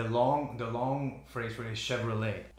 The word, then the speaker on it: Chevrolet: Chevrolet, how do you say it there? – Chevrolet. – Chevrolet? – Chevrolet. – You say like lay, like this? – Chevrolet. – Chevrolet. Nice.